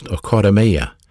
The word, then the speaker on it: oikodome: Edification is a word in the Greek New Testament pronounced oikodome. Oikodome. O-I-K-O-D-O-M-E. Oikodome. Long E on the end of it.